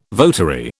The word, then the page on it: votary: British English